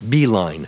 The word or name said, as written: beeline